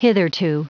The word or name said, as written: hitherto